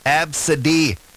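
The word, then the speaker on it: Abcde: On that list, there's A, B, C, D, E, which is pronounced Absidy. Someone named their kid Absidy.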